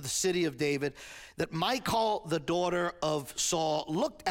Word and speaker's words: Michal: You know, if I was saying it in Hebrew, it's Michal.